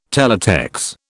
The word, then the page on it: Teletex: British English